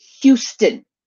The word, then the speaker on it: Houston: They say Houston. Like, it's not Houston. It's Houston. As a Texan, I can tell you, it's Houston. – It's Houston. – Drives me banana pants. – It's Houston.